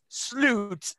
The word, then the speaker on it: slut: Now, I know in Swedish it's pronounced slut, but it means the end or final.